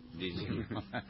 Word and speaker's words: didgeridoo: Didgeridoo. – Didgeridoo, ¿lo dijo bien? – Didgeridoo.